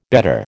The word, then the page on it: bettor: American English